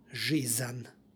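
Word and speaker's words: žizan (жизнь): Sonant se u ovom primjeru nalazi na kraju reči, ispred njega stoji suglasnik, stoga se između njih radi lakšeg izgovora dodaje nepostojano a, stoga izgovor žizan.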